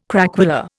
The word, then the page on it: craquelure: Indian